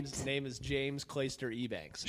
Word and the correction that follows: Eubanks: Maybe Eubanks. It's E-U Banks. But I think it's pronounced maybe Eubanks.